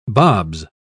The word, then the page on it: bobs: British English